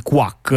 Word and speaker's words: QWAC: si scrive Q. Eh, WAC, eh, però quindi l'ovvia pronuncia quack come, eh, le, mm, come, come chi, le, papere. Come chi, chiunque lo pronuncerebbe così.